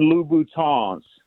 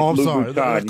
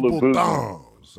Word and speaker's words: Louboutin: And then most importantly, so the women that listen to the show don't start tagging you on YouTube, they are Christian Louboutins. You got to pronounce it with the French Louboutins. You can't go Louboutins.